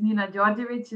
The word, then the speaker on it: Nina Djordjevic: So as you said, my name is Nina Djordjevic, is the correct pronunciation.